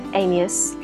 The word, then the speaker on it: Amias: This week I'm talking with Claire, Louise, Amias, who will be bringing the Masks of Afraben to the space in February.